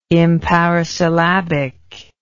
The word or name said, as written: imparisyllabic